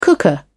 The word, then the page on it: cooker: British English